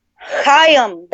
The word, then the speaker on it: Chaim: Chaim. That's how you fucking... That's why I kept calling him... Chaim Weitz. Chaim. That's how you say his name.